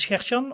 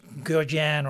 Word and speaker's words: Gert-Jan: Should I say my name is Gert-Jan, or should I say my name is Gert-Jan, or, you know, something like that? And I always choose to say my name is Gert-Jan, because otherwise I'm gonna assume that the conversation partner is not able to pronounce Gert-Jan, and I would rather not. So I'm keeping it at Gert-Jan, but I listen to Gert, Gert-Jan, Jansen-san in Japan.